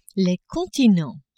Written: continents

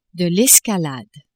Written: escalade

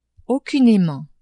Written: aucunement

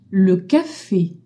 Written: café

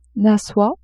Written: soie

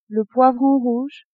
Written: poivron rouge

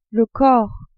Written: corps

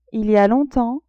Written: il y a longtemps

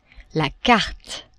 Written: carte

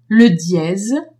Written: dièse

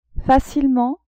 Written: facilement

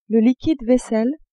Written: liquide vaisselle

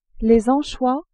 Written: anchois

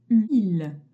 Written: ile

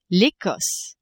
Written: Ecosse

